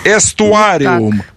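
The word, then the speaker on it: aestuarium: Эстуариум. АЭ читается как Э в латыни, дорогая.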